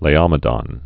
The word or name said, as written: Laomedon